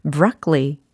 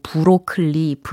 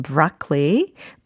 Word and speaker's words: broccoli: broccoli, broccoli 이겁니다. 어때요? 브로콜리는 영어로 broccoli, 이렇게 해주셔야 되겠습니다. 뭐, 브로클리, 브로컬리, 브로콜리. 여러 가지 발음들이 있더라고요. 그쵸? 브로클리 아니고 브라클리, 브로컬리 아니고 브라클리, 브로콜리 아니고 브라클리.